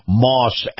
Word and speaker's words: Maus: All right, my conversation with Chris Markowski is being brought to you by Moss Acura. Moss is spelled M-A-U-S.